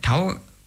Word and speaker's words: Tau: also das ist der griechische Buchstabe TAF, oder wird auch im Deutschen Tau ausgesprochen.